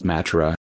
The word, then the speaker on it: Mathura: Krishna was said to have been born at Mathura, pronounced Matra,